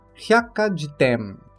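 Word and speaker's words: hyakajitem (百科事典): o qual pronunciamos hyakajitem.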